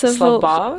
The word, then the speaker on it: Svalbard: the Svalbard Islands. Now, I do apologise if I pronounced that wrong. – I think it's Svalbard.